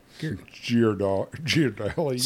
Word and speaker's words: Ghirardelli: Like, was it Ghirardaldi? Ghirardaldi? – You so mispronounced the word. – The Ghirardaldi. The Ghirardaldi chocolate. – I don't think the listeners tune in to the straight cut for, you know, accurate pronunciation. – Or I think that's been pretty well established. – of analogies or anything like that. – How do you say it? Ghirardelli? – Ghirardelli.